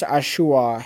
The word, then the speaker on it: Achiuwa: And Precious Achiuwa had 11 points. That's how you pronounce his name.